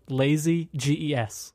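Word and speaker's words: Iazyges: And the Lazyges, which is actually, that's the correct pronunciation. I heard that word too many times in YouTube videos, but it's a funny name because it's spelled Lazy G-E-S. – Oh. – So it's Lazyges.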